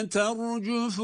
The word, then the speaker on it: تَرْجُفُ: يَوْمَ تَرْجُفُ الرَّاجِفَةِ الرَّاجِفَةِ Bakınız, يَوْمَ تَرْجُفُ derken ter, ra kalın. تَرْجُفُ ra burada şeddeli ra da üstün harekalı için o da kalın. – يَوْمَ تَرْجُفُ الرَّاجِفَةِ